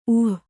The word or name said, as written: ಊ